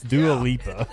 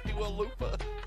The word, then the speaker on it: Dua Lipa: The liner and also mispronouncing the name. It's Dua Lipa. – Dua Lipa.